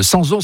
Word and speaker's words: Sampzon: Le Sanson, ça s'écrit S-A-M-P-Z-O-N, je le précise.